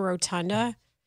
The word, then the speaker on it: rotunda: Dude, even just chilling on the rotunda. Just stand on the rotunda and just watch the game. – You said that wrong. Rotunda. – Oh, did I? – You didn't see in the Pittsburghese.